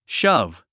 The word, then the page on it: shove: ʃʌ́v; シャァブ